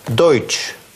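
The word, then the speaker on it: Deutsch: Сочетание «э» и «у» по-немецки читается как «ой». Например, слово «дойч». А теперь внимание. Вместо одной русской буквы «ч» мы видим целых четыре. Почему? Потому что вот эти три буквы передают звук «ш». Когда мы добавляем «т», то это читается как «ч».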